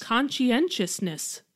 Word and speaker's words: conscientiousness: It's like consciousness, but with an extra syllable. – Yeah. – No, two syllables. – Yes. – Conscientiousness.